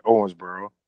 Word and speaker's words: Owensboro: Owensboro.